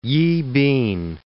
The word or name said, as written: Yibin